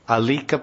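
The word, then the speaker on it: alīka: Nayasat yat paro dharmo yiti ho vacha bur iyam sarvam shodam alamanye ritelika param naram alika param naram alika param naram manye rite alika. You know what alika means? A L long I K means alika means the most heinous liar.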